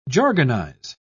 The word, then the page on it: jargonize: British English